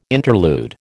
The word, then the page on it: interlude: American English